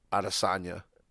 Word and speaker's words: Adesanya: Adesanya.